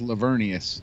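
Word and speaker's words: Laveranues: Yeah, and his name is spelled, like, incorrectly to how you pronounce it or some shit like that. – Yeah. – It's like Lavera News or something. – Lavera News. – Holy shit. – Yeah. – Look at all these. – But it's in the chat pronounced Lavernius.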